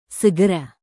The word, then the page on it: segera: indonesian